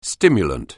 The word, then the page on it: stimulant: anglais